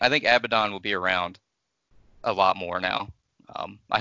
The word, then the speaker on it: Abaddon: Abaddon. Right? Yep. Am I saying it right? – Yeah.